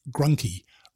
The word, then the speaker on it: Grunke: However, my husband's family is from the Midwest and now Southern US, and they pronounce it Grunky. Rhymes with monkey."